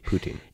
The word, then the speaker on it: poutine: Putin.